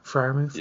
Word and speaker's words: Freiermuth: I think that's how you say his name. Freiermuth.